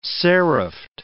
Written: seriffed

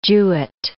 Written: Jewett

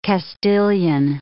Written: Castilian

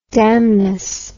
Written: damnous

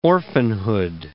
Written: orphanhood

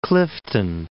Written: Clifton

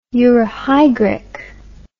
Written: euryhygric

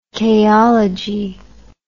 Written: chaologist

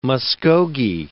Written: Muskogee

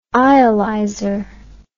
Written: eyelyzer

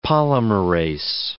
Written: polymerase

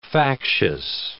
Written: factious

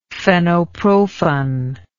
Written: fenoprofen

